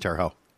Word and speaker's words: Terre Haute: I know how it's spelled, but it's pronounced Terre Haute.